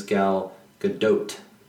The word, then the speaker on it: Gal Gadot: I guess how you pronounce her name is Gal Gadot.